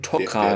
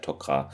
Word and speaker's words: Tok'ra: Äh, spä ja, später auch, aber hier steht von Tokra und äh, später heißt es auch der Tokra. – Ja. Interessanterweise wird es hier auch richtig ausgesprochen, nicht wie in der vergangen vorherigen Folgen Tokra, jetzt ist hier Tokra wieder.